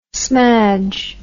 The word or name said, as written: smadge